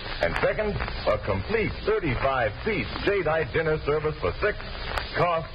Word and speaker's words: Jadeite: It's spelled J-A-D-E-I-T-E, Jadeite.